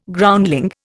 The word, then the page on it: Groundling: Indian